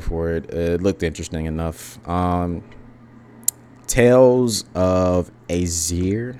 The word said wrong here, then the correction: Arise: I think that's how you say it. Yeah. Tales of Arise, I'm sorry.